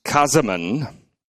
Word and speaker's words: Käsemann: Käsemann says, and I'll use the German pronunciation as best I can, Ernst Käsemann, page 22 of his world-famous commentary on Romans, 1980, produced by William B.